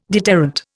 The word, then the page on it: deterrent: Indian